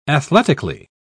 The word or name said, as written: athletically